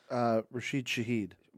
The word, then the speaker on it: Rashid Shaheed: Rashid Shaheed.